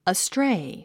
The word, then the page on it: astray: əstréi; アストレェイ